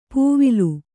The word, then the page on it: ಪೂವಿಲು: pūvilu